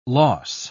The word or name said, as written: loss